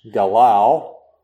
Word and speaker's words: galal: The word that's pronounced galal, and the other word is al.